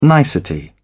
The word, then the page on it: nicety: British English